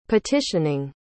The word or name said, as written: petitioning